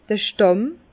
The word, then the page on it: d'r Stàmm: Bas-Rhin; Herrlisheim